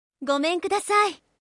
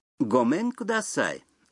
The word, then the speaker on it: ごめんください: Гомен кудасай. – Гомен кудасай означает «здравствуйте» или «прошу прощения».